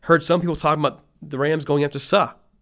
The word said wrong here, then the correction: Suh: or sorry, Suh. I always say his name wrong. Suh.